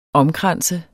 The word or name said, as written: omkranse